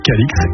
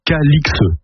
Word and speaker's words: Calix: Le nom était autrefois prononcé Cali, comme le montrent diverses formes anciennes du XIIIe au XVIIIe siècle, mais l'orthographe aberrante Calix a entraîné la prononciation actuelle Calix. Voilà pourquoi nous prononçons Calix depuis plus de 300 ans.